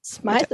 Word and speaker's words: Smyth: Smyth ist richtig ausgesprochen, ne? – Smyth ist genau. Richtig ausgesprochen. – Also wie Smith, nur mit Y.